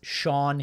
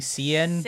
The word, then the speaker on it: Sian: Her name is Sean Heder. I incorrectly said, I think, Cian or something like that, because I've never seen Sean spell the way that she spells it, S-I-A-N.